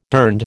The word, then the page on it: durned: American English